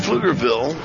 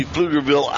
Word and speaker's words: Pflugerville: It's actually, it's a P and then the word field, but the P is silent, similar to the name Pflugerville, which is how they named the facility here.